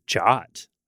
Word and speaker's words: JWT: But the advisory says JWT is pronounced as JOT.